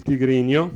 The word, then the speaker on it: tigrino: Le lingue più parlate, attenzione, che sono il tigrinio, si scrive tigrino ma si dice tigrinio, e il tigre.